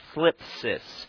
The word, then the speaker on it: thlipsis: Now, that word trouble in the Greek is the word thlipsis. And I don't have a lisp. That's actually how you say it. It's T-H-L-I-P-S-I-S, thlipsis, which means tribulation, affliction, burdens, conflicts.